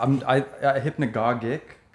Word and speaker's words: hypnagogic: Hypnagogic.